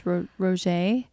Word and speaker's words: rosé: How do you say this? Rosé? – Sure.